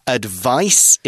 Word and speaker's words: advice: Advice is a noun, it's got a C and it kind of sounds like ice. Advise has got that kind of Z sound, although it's spelt with an S, A-D-V-I-S-E.